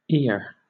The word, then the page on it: ere: British English